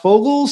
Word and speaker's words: Vogel: His name looks like Vogel, but it's pronounced Fogel.